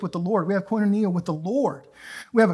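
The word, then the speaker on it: koinonia (κοινωνία): That word again, koinonia. Say it. Koinonia. I've been butchering that since like 2002.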